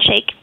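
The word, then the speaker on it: Shaich: Which was kind of the thrust of the article in The New Yorker today with your founder, Ron Sayich? Is that how you say it? – Ron Shake.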